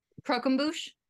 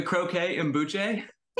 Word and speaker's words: croquembouche: Croquembouche? – Yeah, the croquet and bouche.